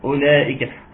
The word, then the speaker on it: أولئك: نعم الواو عليها صفر مستدير، تكتب ولا تنطق، مفيش واو في كلمة أولئك. أولئك، طب دي حاجة تكتبيها؟